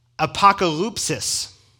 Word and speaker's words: apokalypsis: And it's the word apokalypsis.